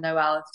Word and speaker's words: Noel: I often have to spell it for people and I'll say N-O-E-L, like Christmas, Noel.